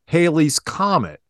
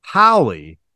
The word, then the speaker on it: Halley: Haley's Comet, properly pronounced Halley, as in rhymes with valley, but commonly mispronounced as Haley, a comet which orbits the sun near Earth about every 75 years.